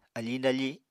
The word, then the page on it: afiñ dafi: Apatani